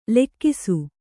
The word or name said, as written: ಲಾಘಿಸು